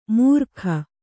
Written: ಮೂರ್ಕ

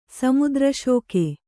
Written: ಸಮುದ್ರಶೋಕೆ

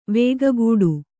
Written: ವೇಗಗೂಡು

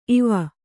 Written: ಇವ

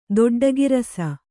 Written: ದೊಡ್ಡಗಿರಸ

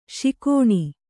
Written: ಶಿಕೋಣಿ

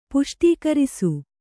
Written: ಪುಷ್ಟೀಕರಿಸು

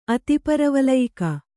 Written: ಅತಿಪರವಲಯಿಕ

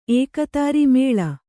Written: ಏಕೋದರ

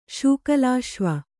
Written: ಶೂಕಲಾಶ್ವ

